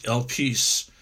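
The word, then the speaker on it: elpis (ἐλπίς): So in verse 11, we've got the word hope, which is pronounced elpis, which means confidence, hope, to anticipate, usually with pleasure, to anticipate things.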